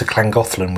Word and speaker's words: Llangollen: You could come up to Llangollen with us. – Don't be rude. – I'm not. – Okay. – That's how the Welshies pronounce it.